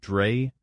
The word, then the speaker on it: Drais: And according to them, it was a German inventor named Carl von Drais, D-R-A-I-S. I guess that'd be sort of a French pronunciation, Drais. – Well, if it's von, then it would be Germanic, which would be Drais.